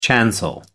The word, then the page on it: chancel: American English